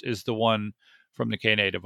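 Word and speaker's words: Knative: Is it K-Nave? How do you pronounce that? – I thought that? – It was K-Native, no? – K-Native? Yeah, K-Native. The K-Native book author.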